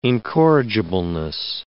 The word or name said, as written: incorrigibleness